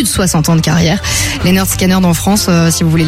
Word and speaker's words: Lynyrd Skynyrd: Ma mère disait Lynyrd Skynyrd, tu vois, il y a plein de... – Oui, parce que ça s'écrit avec un Y, mais sur leur premier album, l'album s'appelle Pronounced Lynyrd Skynyrd.